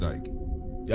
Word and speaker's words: Icke: That's David Icke, by the way. That's Icke. His name. Yep, it's Icke. – I've never heard it pronounced. I've only read it. – Yep, that's him. – I thought it was I thought it was Icke. – Nah, it's Icke.